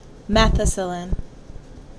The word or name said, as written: Methicillin